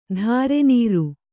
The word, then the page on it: ಧಾರೆನೀರು: dhārenīru